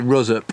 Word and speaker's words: Ruswarp: We say Rusup, but it is spelt Ruswarp.